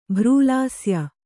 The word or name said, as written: ಭ್ರೂಲಾಸ್ಯ